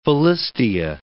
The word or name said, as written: Philistia